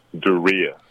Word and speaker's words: Darius: It's a little confusing because it's spelled like Darius, but it's actually pronounced Daria.